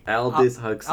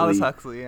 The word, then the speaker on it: Aldous Huxley: Aldous Huxley. – Aldous Huxley, yeah. – Orgy Porgy, and that's where I ended it. – The last line you wrote was Aldous Huxley Orgy Porgy.